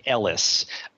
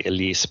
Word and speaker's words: Elice: It's Rick Ellis, even though it's spelled E-L-I-C-E. And a lot of people might say Elise, but that is his name.